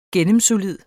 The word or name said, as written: gennemsolid